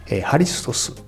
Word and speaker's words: ハリストス: 拝 OK 正教会の方がダメという裏にはですねこんな裏の事情もあるわけですこの正教会日本では日本ハリストス正教会と言いますハリストスというのはキリストのギリシャ語式の発音ですね 1861 年にロシアからやってきた司祭のニコライという人が伝えました。